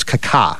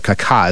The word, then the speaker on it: Kaká: Okay, I should say his name right. It's Kaká, right? I was told this before. Kaká is how you say it, not Kaká, but Kaká.